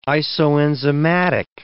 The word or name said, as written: isoenzymatic